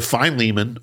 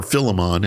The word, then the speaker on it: Philemon: To Philemon, or Philemon, if you want to sound fancy. that's the way the Brits say it. We usually say Philemon because we like to, I don't know. We're American. We can talk however we want to.